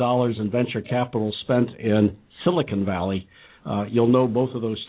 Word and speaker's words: Silicon Valley: And by the way, it's Silicon Valley. It's not silicone.